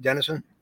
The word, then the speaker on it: Denison: And did I pronounce it right, Denison? – Yes, sir.